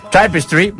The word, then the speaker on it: Tapestry: jo ho pronunciaré a la Baix Llobregatina, Tapestry, com es diu això? – Tapestry? – Tapestry, molt bé.